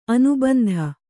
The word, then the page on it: ಅನುಬಂಧಿ: anubandhi